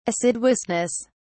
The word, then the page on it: assiduousness: British English